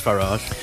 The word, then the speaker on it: Farage: So, I mean, Nigel Farage, is it Farage? I can never pronounce his name. – It is Farage. – Nigel... – Rhymes with garage. – It does, doesn't it? Or Nigel Farage, as in garage.